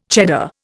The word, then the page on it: Cheddar: Indian